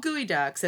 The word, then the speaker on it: geoduck: And so they're called gooey ducks, and they're spelled G-E-O-D-U-C-K. So it doesn't sound like gooey duck, but that is how they're pronounced.